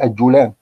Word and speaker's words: الجولان: هناك أيضا كلمة الجولان السورية تمام كده نقول الجولان لكن هي الجولان بفتح ايه بفتح الجيم لكن الخطا كله بينطقها ايه الجولان